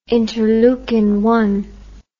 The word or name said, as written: interlend